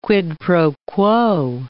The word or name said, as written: quid pro quo